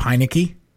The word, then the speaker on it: Heinicke: Heinicke.